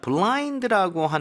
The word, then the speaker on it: blind: 자, blind라고 하는 것은 영어로 그대로 blind라고 쓰면 될까요? – 네. 그런데요, 이름절로 발음하셔야 돼요. 블라인드가 아니고 blind. – 그렇습니다. – blind. – blind. 아이의 강세를 많이 주면 좋을 것 같아요.